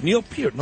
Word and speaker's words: Neil Peart: That's Neil Peart, no kidding.